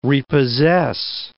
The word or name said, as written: repossess